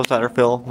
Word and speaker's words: Satterfield: My name is Michael Satterfield. My last name is spelled S-A-T-T-E-R-F-I-E-L-D.